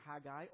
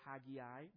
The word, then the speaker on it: Haggai: By the way, you can pronounce it Haggai or Haggai. Both pronunciations are fine.